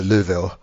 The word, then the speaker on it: Louisville: I mean, on my road trip for the election last year, we did go to Louisville where there is nothing.